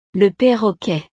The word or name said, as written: le parroquet